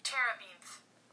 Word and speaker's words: terebinth: The correct way to say it is terebinth. And that was provided by Google. so just so that even if you heard it from me wrong, the right way to say the tree is terebinth.